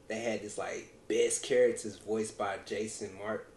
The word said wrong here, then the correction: Marsden: I think I'm saying his name wrong. Marsden.